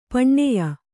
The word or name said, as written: ಪಣ್ಯ